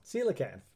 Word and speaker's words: coelacanth: So, coelacanth.